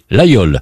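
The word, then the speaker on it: Laguiole: Alors, au cœur de ce plateau, un village est connu dans le monde entier, l'Aïole. Ça s'écrit l'Aguiole, mais on prononce l'Aïole.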